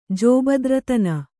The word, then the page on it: ಜೋಬದ್ರತನ: kannada